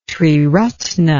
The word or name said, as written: triratna